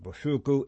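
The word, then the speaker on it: Bociurkiw: I'm going to pronounce it Boshurku. It's spelled capital B, O, C as in Charlie, I, U, R as in Roger, as in Roger, K as in kangaroo, I, W.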